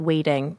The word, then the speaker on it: Weighting: The short that was at Sundance is called The Arm, and the South by Southwest short is called Waiting, but it's spelled, W-E-I-G-H-T-I-N-G.